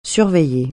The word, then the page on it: surveiller: French